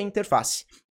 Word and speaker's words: interface: Qual que é a sílaba tônica? É interface, é o turn? Não, é interface, interface, interface, é o N.